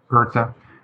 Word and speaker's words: Goethe: My first question to you, is how do you pronounce his name? – Goethe. It's definitely Goethe.